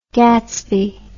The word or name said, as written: Gatsby